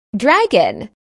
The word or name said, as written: dragon